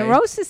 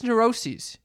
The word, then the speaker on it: neuroses: Neurosis sees kind of rhymes with... – It's just neuroses. It's not neurosis.